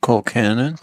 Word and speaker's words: Colcannon: Colcannon? Am I saying that right? – Colcannon.